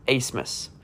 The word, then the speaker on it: Abmas: Kevin O'Banner, who ended up transferring to Texas Tech, and Max Asmus. It's spelled Abmas, but it's pronounced Asmus for whatever reason.